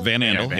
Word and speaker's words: Van Andel: Van Andel.